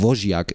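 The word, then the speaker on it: Łosiak: Ich finde auch, also zu sehen, wie Bartosch und also, ne, Achtung, das soll jetzt nicht so affektioniert klingen, aber ich glaube, dieses L spricht man halt wirklich eigentlich wie ein W aus. So, ne? Das machen die Kommentatoren auch immer so, Wojciak ist es eigentlich oder so, musst du Tommy ja.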